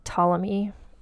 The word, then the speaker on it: Ptolemy: Ptolemy. It's spelled with a P at the beginning, so it's like pterodactyl. And it's hard for me to not say the P.